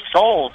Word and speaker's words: Seoul: Oh, yeah, Seoul, South Korea.